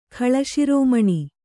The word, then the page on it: ಖಳಶಿರೋಮಣಿ: khaḷa śirōmaṇI